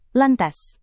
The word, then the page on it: lantas: indonesian